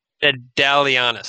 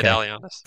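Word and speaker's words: Ndalianis: Angela Nadalius. Nadalianus, sorry. – Okay. – Nadalianus.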